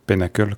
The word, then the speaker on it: PNaCl: Il a été remplacé par PNACL, Penacle, comme on dit, mais bon.